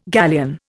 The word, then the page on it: galleon: Indian